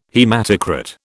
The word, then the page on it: haematocrit: British English